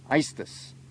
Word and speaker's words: aestus: Well, the word estuary actually comes from a word pronounced aestus. It's spelled A-E-S-T-U-S.